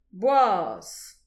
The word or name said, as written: boas